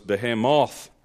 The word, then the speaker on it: Behemoth: According to Strong's Concordance, the audio pronunciation was Behemoth. Is that a stress on the last syllable?